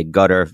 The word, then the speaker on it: Qatar: We could spend a lot of time talking about what's the correct pronunciation of this word. I'll say Qatar. If you'd like to say Qatar, if you'd like to say gutter, you know, feel free.